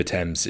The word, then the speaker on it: Thames: The River Thames is the river that runs through London. It's spelt T-H-A-M-E-S, so it's a pretty unorthodox pronunciation, but we're really keen on that sort of thing in Britain.